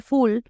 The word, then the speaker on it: full: Se escribe F-U-L-L, pero la U está muy cerradita, lo que les digo las vocales cerraditas. En lugar de full es full, fall, fall, fall.